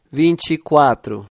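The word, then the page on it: 24: Brazilian Portuguese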